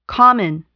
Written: common

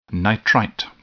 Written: nitrite

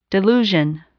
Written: delusion

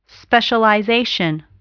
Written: specialization

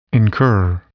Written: incur

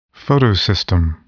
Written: photosystem

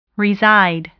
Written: reside

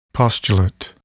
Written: postulate

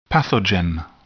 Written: pathogen

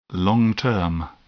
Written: long term

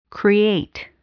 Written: create

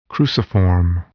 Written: cruciform

